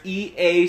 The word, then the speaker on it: Ea: Uh, there's E.A. Sport. No, uh, Ea, I believe is the pronunciation. That one's just EA, but um, Ea, I think.